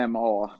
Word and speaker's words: AMA: Är det AMA eller AMA? – Det är AMA, där är punkter emellan.